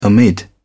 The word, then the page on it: amid: British English